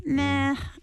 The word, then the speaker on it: meh: If you're talking to younger generation, you're using slang, you could say meh. You don't even say a word. It's just a sound. And I guess you'd spell it M-E-H, and you just hold on to that E-H for a while.